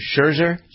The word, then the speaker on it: Scherzer: So, it seems that Max Scherzer, has, how do you pronounce his name? Scherzer. No T.